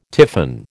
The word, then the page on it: tiffin: American English